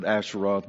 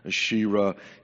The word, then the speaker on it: Asherah: Usually folks pronounce that Asheroth, but it's Asherah.